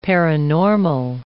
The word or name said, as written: paranormal